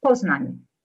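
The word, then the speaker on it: Poznań: Is it Poznan or Poznan where you went to school? How do you pronounce it? I've been there. I love it. How do you pronounce it? – Poznan. – Poznan.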